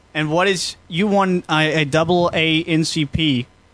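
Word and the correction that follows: NAACP: Double A-N-C-P? – N-double A-C-P. – Is that what I said during the break? Did I say double A-C-P or something? – You said something that was wrong.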